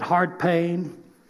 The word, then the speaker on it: Howard Payne: And there they call it Hard Payne. That really is how they pronounce it, Hard Payne College.